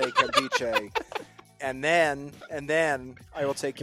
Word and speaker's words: Nkemdiche: And the N is silent, Hodge. How dare you mispronounce it? I even said it earlier for you. Kemdiche.